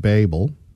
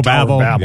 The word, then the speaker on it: Babel: The first was the Tower of Babel. I'm always mispronouncing that. – Babel. You know.